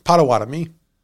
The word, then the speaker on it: Pottawatomie: Lately, so I will correct your pronunciation in as far as American marketing has suggested. It's Pottawatomie.